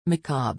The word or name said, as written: macabre